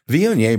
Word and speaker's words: Viognier: Viognier, by the way, is spelled V-I-O-G-N-I-E-R.